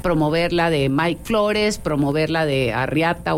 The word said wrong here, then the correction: Arratia: o no, me dicen que lo digo mal, pero bueno. – No, sí, está bien, Arrate. – Arriata le digo yo. Arratia. – Es Arratia. – Ok, sí. Ya lo corregimos.